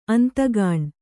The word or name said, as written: ಅಂತಗಾಣ್